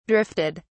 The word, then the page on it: drifted: British English